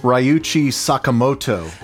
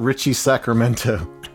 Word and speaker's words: Ryuichi Sakamoto: And so Richie Sacramento, they had a friend who was trying to pronounce the name Ryuichi Sakamoto, and it came out Richie Sacramento.